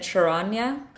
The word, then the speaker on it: Sharanya: I in my head when I was reading it said Charanya. – That is how it's pronounced, and then I saw someone spell it in. Uh, I th- I think that's. I how was it spelled? – S H A R A N Y A.